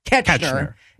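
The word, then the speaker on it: Koechner: David Kochner, is that how that name's pronounced? – Ketchner.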